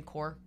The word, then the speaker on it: Corps: Do I say the Marine Corps? – Yeah. – Is that what I say? – Yep. Okay. It's a silent S. It's like the P in pterodactyl. It's like silent.